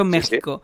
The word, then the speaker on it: México: Con la cosa de la J, y yo toda mi vida he dicho México, sí. Entonces ahora ya sé que se escribe después a mis 31 años, ya sé que se escribe con X, pero lo digo con J por ignorante, o sea, la ignorancia me ha hecho fuerte.